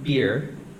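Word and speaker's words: bier: Then he went up and touched the, I think it's pronounced beer, not the kind you drink.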